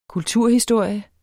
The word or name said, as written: kulturhistorie